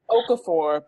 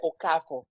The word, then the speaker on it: Okafor: It's Okafor, but if you are Nigerian, you may pronounce it Okafor.